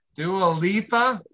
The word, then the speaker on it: Dua Lipa: Was this the DeLupa Dialupa? Why can't I never pronounce her name right? – Dua Lipa? – Yeah, Dua Lipa.